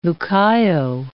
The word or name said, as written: Lucayo